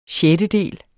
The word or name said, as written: SJETTEDELE